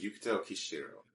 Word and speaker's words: Yukito Kishiro: His name is Yukito Kishiro.